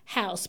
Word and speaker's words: haus: Like, house in Tok Pidgin is house, but it's spelled H-A-U-S, which is like the German way of spelling, and like blood is blut, like B-L-U-T.